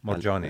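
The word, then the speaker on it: Moorjani: Äh, Anita Mor Jott Ani, Morjani Marjani. Äh, hääldatakse jah, aga J-ga käib see mm.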